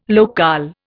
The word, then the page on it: locale: Indian